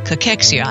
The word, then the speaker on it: cachexia: That's right, cachexia. I actually pronounced it correctly.